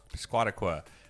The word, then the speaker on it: Piscataqua: It's called, they told us how to pronounce it, and then I forgot. Right. – Piscataqua. Piscataqua.